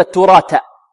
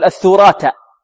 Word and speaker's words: التراث: ربما البعض يقول وتأكلون الثراثة الثراثة، والبعض يقول وتأكلون التراثة، والبعض ربما يقول الثراثة، يقلب. لكن لا، لابد على الطالب أن يركز ينطق الأولى تاء مثناه فوقية، يعني من طرف اللسان مع أصول الثنايا، والتي بعد الألف الأخيرة ينطقها ثاء من طرف اللسان مع ماذا؟ منتهى طرف اللسان مع منتهى طرف الثنايا العليا، هكذا.